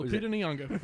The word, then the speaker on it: Lupita Nyong'o: It's Lupita Nyong'o. – Lupita Nyong'o.